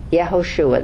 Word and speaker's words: Yehoshua: In Hebrew, Joshua's name is Yehoshua. Yehoshua, that's how you say it, all right?